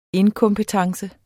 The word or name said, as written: inkompetence